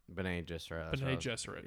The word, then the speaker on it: Bene Gesserit: By the way, the main witch is Bene Gesserit. Or Bene Gesserit.